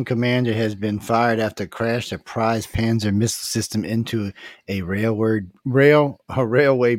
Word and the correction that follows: Pantsir: I'm probably saying it wrong. It's more like Panster, actually. It's Pantsir.